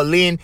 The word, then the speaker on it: Lynde: I used to do this bit with Don Ho, John Wayne, and Paul Lynn. If you guys don't know who is Paul Lynn, you got to Google. His last name is spelled L-Y-N-D-E. He used to be the center square on Hollywood Squares.